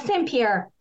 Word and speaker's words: St. Pierre: So first of all, did I get your last name right? Or is it more the French pronunciation? – St. Pierre. So I'm using the English version.